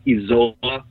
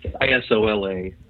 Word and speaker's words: Isola: It's the Italian word for island, and the Italian pronunciation is close to what we're using. There is a Spanish/Argentinian pronunciation, I believe, that's more like Isola.